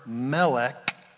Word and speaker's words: Melchizedek: Then a fella by the name of Melchizedek. If you're familiar with Hebrew, you would pronounce this word Melchizedek. Melech means king. Zedek means righteousness. His name means king of righteousness.